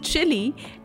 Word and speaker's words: Chile: does resemble something which is pronounced as its name. Yes, the country is Chile and it indeed looks like a long chili. Difference is the spelling. It is C-H-I-L-E, which is the South American country.